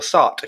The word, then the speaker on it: Sartre: On the topic of nonsense, Jean-Paul Sartre, or Sartre, if you want to say it properly, which I won't.